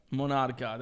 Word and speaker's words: Monarca: Monarca? – Monarca.